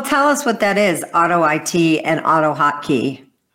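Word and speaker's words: AutoIt: Auto IT, per forum pronounced auto IT. He said, I suspect as in automate IT. I'm not sure what that means. I'm sure you probably do, Mike. – Yeah, I think he's correcting my pronunciation, and I think he's probably right, right? It's probably supposed to be, I probably should be saying auto IT, not auto it.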